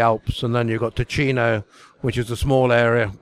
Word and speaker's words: Ticino: Tessin, which is the French way of pronouncing it. Ticino is the Italian way of pronouncing it, and Ticino is Italian-speaking.